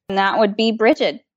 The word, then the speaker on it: Brigid: There are a variety of pronunciations and spellings. I prefer B as in bump, R, I, G as in ghost, I, D as in Dan. That's okay. How I typically spell it if I'm referring to her. But there's also Breed, which is spelled like the word bride, Bridget with a T at the end. And I have not heard of any incorrect pronunciations, unless you're saying like Brigid or something like that, but Brigid, typically you can't go wrong.